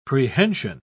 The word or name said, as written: prehension